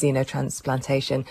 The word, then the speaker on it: xenotransplantation: And you're going to tell us a little bit more about xenotransplantation. Is that the correct pronunciation? – Yeah, very good. Xenotransplantation.